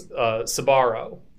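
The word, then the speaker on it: Sbarro: You guys are saying it wrong. It's Sabaro.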